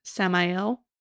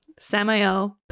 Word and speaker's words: Samael: Samael. – You're saying it right. – Am I? It feels like it's coming out of my mouth. Yeah. Samael, posing as a ruler of Ilion with his dark hound doggies.